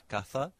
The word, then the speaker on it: caza: This is known in Spanish as distinción, and in some places, the word casa, C-A-S-A, which means the house, it can be contrasted with the word casa. Well, I'm saying that with a very English pronunciation, C-A-Z-A. So casa, house, with an S, and caza for the hunt, C-A-Z-A.